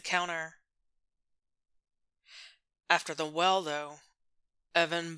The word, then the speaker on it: Evan: Evan. Evan, Evan, Evan. Four letters. Two syllables. No plosives. Just vowels separated by a soft fricative.